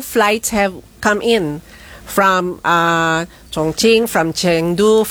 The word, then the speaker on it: Chongqing: Ang, everybody thinks it's Chongqing, but it's a Chinese, the Q is pronounced as CH din.